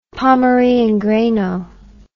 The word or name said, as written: pommery and greno